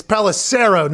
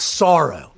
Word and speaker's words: Pelissero: It's Pelissero, not Sorrow.